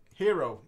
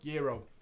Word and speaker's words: gyro: I need to get that gyro off the screen because I'm insanely hungry now. – Right. Hero, Euro. You're saying it wrong. Euro. – You said gyro, I'm copying you.